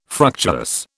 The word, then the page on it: fructuous: British English